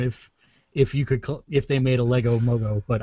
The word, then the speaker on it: Mogo: Now I'll just be waiting for my Lego Mogo set.